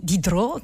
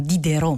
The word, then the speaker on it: Diderot: Denis Diderot, che noi italiani molto spesso chiamiamo Diderot, ma in realtà si pronuncia Diderot,